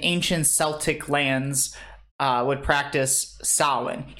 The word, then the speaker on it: Samhain: He talks about, again, we go back to the pagan holiday of Samhain.